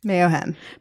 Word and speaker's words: Mayohem: You're listening to Scott Wise, Roby Martin, and the brand manager of Mayohem, correct? Did I say it right? – Mayohem.